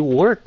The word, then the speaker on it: work: còn cái chữ work này đó các bạn phải nghe nó were work